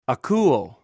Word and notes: akouw: greek

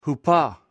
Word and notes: hupo: greek

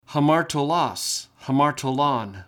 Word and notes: hamartolos: Greek

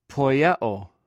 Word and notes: poiew: greek

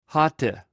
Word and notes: hote: greek

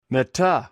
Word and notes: meta: greek

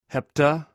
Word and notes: hepta: greek

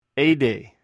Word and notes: hdh: Greek

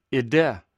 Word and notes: ide: greek

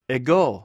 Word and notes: egw: Greek

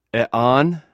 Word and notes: ean: greek

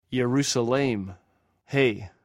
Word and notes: ierous: greek

